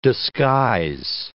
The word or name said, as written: disguise